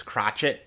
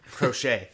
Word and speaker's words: Crochet: Garrett, Crotchet, Crochet. I'll give it right there because you put the pronunciation right in the rundown.